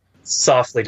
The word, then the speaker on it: Soflete: Softly. It's SOFLETE.